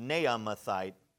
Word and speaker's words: Naamathite: And then we have Zophar the Naamathite, is actually how it's pronounced, Naamathite.